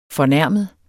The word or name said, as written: fornærmet